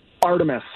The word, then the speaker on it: Artemis: How do you pronounce her name again? – Artemis.